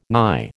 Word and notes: nigh: American English

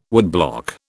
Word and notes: woodblock: British English

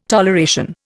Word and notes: toleration: Indian

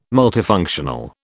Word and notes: multifunctional: British English

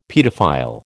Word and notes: paedophile: American English

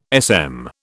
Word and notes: SM: British English

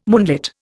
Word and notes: moonlit: Indian

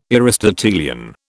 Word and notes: Aristotelian: British English

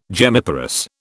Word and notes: gemmiparous: British English

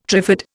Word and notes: trifid: Indian

